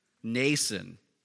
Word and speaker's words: Mnason: I always wondered how to pronounce that. And then I looked at a Bible commentary that told me, Mnason of Cyprus.